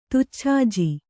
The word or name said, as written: ತುಚ್ಛಾಜಿ